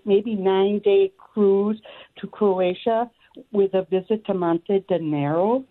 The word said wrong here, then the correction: Montenegro: Am I saying that right? – Monte de Negro? Montenegro? – There we go.